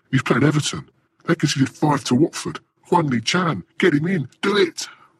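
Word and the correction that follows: Hwang Hee-chan: Huang He Chan is the correct pronunciation.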